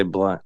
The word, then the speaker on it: Blount: But it's, yeah, but they say blunt. It's B-L-O-U-N-T.